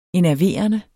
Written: enerverende

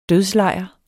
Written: dødslejr

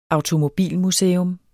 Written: automobilmuseum

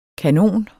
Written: kanoner

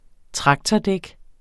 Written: traktordæk

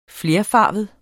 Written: flerfarvet

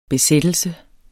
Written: besættelse

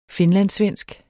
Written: finlandssvensk

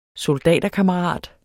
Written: soldaterkammerat

